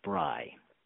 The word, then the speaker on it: Bri: It is Rhymes with Fry because Fry rhymes with Bri.